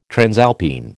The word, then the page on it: transalpine: American English